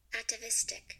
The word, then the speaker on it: atavistic: Did I say that right? – Atavistic. – Fuck yes. yeah, I did.